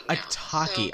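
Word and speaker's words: Atake: We figured out which one, how to pronounce it. – I think it was a Taki. So, I think it was a Taki. It took us a few weeks to figure it out.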